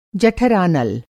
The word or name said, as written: जठरानल